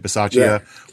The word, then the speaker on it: Bisaccia: That's how you say it, Bisaccia, with him taking over.